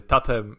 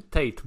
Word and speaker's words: Tate: Teraz pytanie jest jak wymowa. Tate czy Tate Multimedia? E, no załóżmy że, załóżmy, że jesteśmy w Polsce, więc Tate Multimedia. – Daddy Multimedia. – Daddy Multimedia. O, o, te, o jeszcze lepiej.